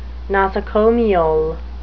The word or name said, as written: nosocomial